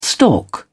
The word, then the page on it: stalk: British English